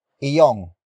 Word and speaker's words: iyong: It consists of two words, starting with iyong. Iyong. Iyong. Iyong is spelled as I-Y-O-N-G.